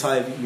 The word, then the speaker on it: Tyvi: All right, well, my name is Tyvi.